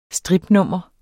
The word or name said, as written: stripnummer